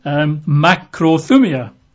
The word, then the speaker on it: makrothumia: Makrothumia, which if you're taking notes, you would spell that in English as M-A-K-R-O, makrothumia, T-H-U-M-I-A.